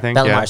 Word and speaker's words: Bellemare: That image of Reeves, Kadri, Bellemare. I'm saying the name right, right? – Yep. Bellemare, sorry.